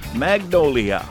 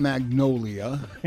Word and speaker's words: magnolia: We're back with the Mike Winters Lawn and Garden Show, and we're talking magnolia. – Not magnolia. It's magnolia.